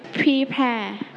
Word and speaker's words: prepare: Prepare. – Prepare. Let's check together. Everybody say it together. Prepare. – Prepare. – Prepare. – Prepare. – Okay. So we stretch on the second syllable.